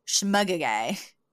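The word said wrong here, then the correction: shmegegge: Shmugagay? – Shmugagi. – Shmugagi. – Shmugagi. – Shmugagi. Which is Yiddish for baloney, nonsense, when you're full of hot air.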